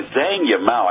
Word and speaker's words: Zhang Yimou: Yeah, Shadow is a movie by Zhang Yimou. I believe that's the way you pronounce his name.